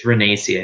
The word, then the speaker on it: Renacci: It's Renacci.